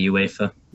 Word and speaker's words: UEFA: Is that how you're supposed to say it or is that just how the fancy British announcers say it? – They it's just that's the way they pronounce it. They just say UEFA.